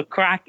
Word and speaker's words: craic: So crack is an Irish word. It means fun. It's spelled C-R-A-I-C. So it's different from maybe the crack people are used to.